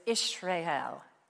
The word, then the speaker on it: Israel: And I found out the proper pronunciation of Israel is Israel, Israel.